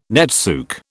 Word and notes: netsuke: British English

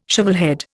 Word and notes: shovelhead: Indian

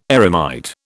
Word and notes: eremite: British English

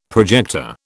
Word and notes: projector: British English

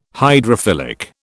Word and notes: hydrophilic: British English

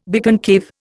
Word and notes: biconcave: Indian

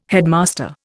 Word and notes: headmaster: Indian

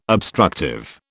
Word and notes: obstructive: British English

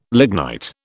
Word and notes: lignite: British English

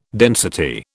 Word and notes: density: British English

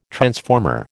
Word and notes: transformer: American English

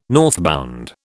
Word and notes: northbound: British English

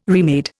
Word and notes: remade: Indian

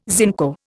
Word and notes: zinco: Indian

